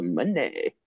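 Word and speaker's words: Moné: Because her song literally goes, Money. – So it's Mone. – Yeah, Mercedes Mone. – Why couldn't you just fucking spell it money, dude? – Because it's spelled M-O-N-E. With a little tilde at the top of the E.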